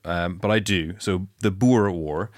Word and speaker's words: Boer: I called it the Boer War. – Okay. – Right. – And it's actually pronounced Boer.